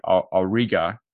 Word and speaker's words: Auriga: Yeah, you know, once the what ends up happening is this installation, this giant ship called the Auriga, I think I'm saying that right. – Yep.